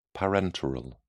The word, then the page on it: parenteral: British English